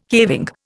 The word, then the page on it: caving: Indian